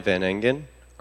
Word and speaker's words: Van Engen: Ryan Van Engen, R-Y-A-N, V-A-N, E-N-G-E-N.